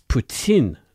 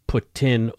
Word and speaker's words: poutine: It's Poutine, not Putin or Poutine.